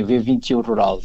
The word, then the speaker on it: V21 Rural: É assim que se pronuncia? – É assim, V21 Rural.